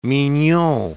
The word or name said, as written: mignon